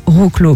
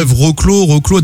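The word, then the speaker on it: Wrocław: Je pense que ça se prononce Wroclaw, mais en fait, il y a un W. – Ouais, Wroclaw, donc ça s'écrit W-R-O-C, un espèce de L-A-W.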